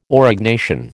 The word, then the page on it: Aurignacian: American English